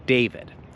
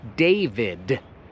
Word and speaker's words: David: David. I widzisz, tam jest litera I. To się wymawia D-W-I-D. David. To nie jest David.